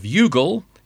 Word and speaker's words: Youghal: which is titled Y-O-U-G-H-A-L Basting Spoon. And how are you going to pronounce that? No, it's not the Irish provincial town of Youghal.